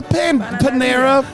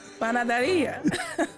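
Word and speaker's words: panadería: It's a panera. – Panaderia? – Panaderia.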